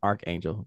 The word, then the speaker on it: Archangel: Yes, Archangel. Yes. – Not Arch. Arc. And I always mispronounce that.